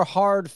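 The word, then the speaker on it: ha (ห้า): So in Thai, the number 5 is pronounced ha, H-A.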